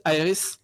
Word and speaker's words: Aherys: My nickname is Aherys. – Yeah. – And for people who know, yes, it's just a different pronunciation from Aherys of Final Fantasy VII.